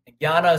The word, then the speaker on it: Jnana: Jnana is spelled J-N-A-N-A.